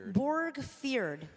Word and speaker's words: Borgfjord: Borgfjord.